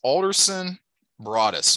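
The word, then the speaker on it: Alderson Broaddus: Keep me honest, Alderson Broadus. Am I saying that correctly, Sean? – Yep.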